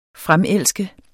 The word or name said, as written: fremelske